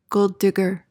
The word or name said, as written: gold-digger